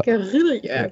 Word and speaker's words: Guerilla: Ja, oder halt auch PR oder Marketing, also mit der Spezifikation vielleicht mit Gorilla, oh, Entschuldigung, ich kann es nicht aussprechen. – Guerilla, genau.